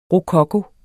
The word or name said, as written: rokoko